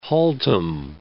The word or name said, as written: Haltom City